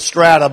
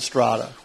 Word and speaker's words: Strada: Well, we pronounce it Strada, but most Strada. 99% okay. of the people pronounce it Strada. – Well, and actually, the correct pronunciation is Strada.